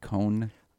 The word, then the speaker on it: koan: How you pronounce it, Cone? – I think so. It's like the Jewish last name, like Cohen.